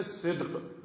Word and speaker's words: صدق: سواد دال قاف، قاف دو نقطے والا، اس سے ہوتا ہے صدق، اس کی مخالف حالت کا نام ہے کذب، جھوٹ.